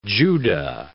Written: Judah

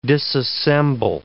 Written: disassemble

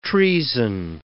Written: treason

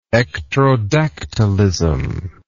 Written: ectrodactylous

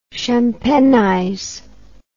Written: champenize

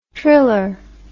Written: triller

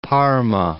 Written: Parma

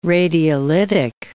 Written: radiolytic

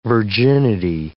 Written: virginity

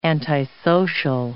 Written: antisocial

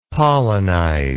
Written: pollinize

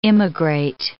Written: immigrate

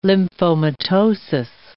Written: lymphomatosis

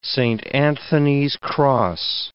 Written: Saint Anthony's cross

